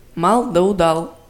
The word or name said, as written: мал да удал